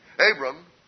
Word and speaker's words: Abram: And this Assyrian down there in Ur called Abram, without the other syllable vowel in it, Abram, one day was walking in the field, and God talked to him.